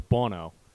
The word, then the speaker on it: Buono: So, like, my last name is Bono. It's spelled B-U-O-N-O. People say Buono, Bono, Sonny Bono. Like, you, like, it's just nothing but the right one. So I always say, okay, B-A-W-N-O.